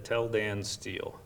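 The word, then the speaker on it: Tel Dan Stele: It's pronounced the Tell Dan Steele. Now, we're not telling Dan Foster to go steal, but it's referring to a place that a stone tablet was found.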